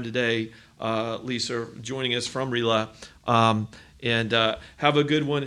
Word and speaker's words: RILA: So it's RILA. And for anybody who's heard Brand Elverston say RILA for 30 years, that is a mispronunciation. It's RILA.